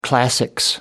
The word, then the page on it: classics: British English